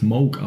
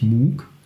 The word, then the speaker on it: Moog: der übrigens Moog ausgesprochen wird und nicht Moog nur mal so nebenbei, mhm.